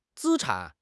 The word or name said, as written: 资产